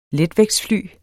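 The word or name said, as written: letvægtsfly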